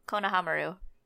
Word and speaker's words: Konohamaru: Konohamaru.